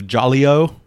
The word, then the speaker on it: Jolio: So then we see Detective Jolio, that's how it's pronounced, is doing like weird kook cases.